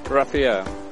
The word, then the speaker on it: Rafael: P-I-E-R-R-E. – Hi, my name is Joanne. What's your name? – Raphael. – Raphael, can you spell that for me? – R-A-F-A-E-L.